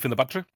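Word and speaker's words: Fenerbahçe: Und steht im Moment auf Platz 3 hinter Galatasaray und Fenerbahce, aber okay. Vor Besiktas. – Ne, und genau so spricht man die Vereine aus.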